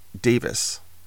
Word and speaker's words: Davies: As an aside, it seems in the UK, they pronounce Davies as in Ray Davies, Davis.